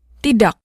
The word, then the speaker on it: tidak: Tidak ends in a K. The K at the end of a word is often pronounced with what's called a glottal stop.